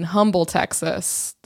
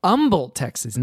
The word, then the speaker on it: Humble: But you would consider the Deerbrook Mall in Humble, Texas, that's your mall. – Yes, although it's pronounced Humble, Texas, not Humble, Texas.